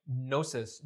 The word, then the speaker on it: gnosis: The word knowledge, gnosis, has the root word from the Sanskrit jna, J-N-A, which is where we get the word jnana, meaning knowledge. And, or the Greek gnosis, G-N, which you pronounce with an N, comes from also the root, relates to the Sanskrit jna, or pranya, to know, to have direct insight into any phenomenon.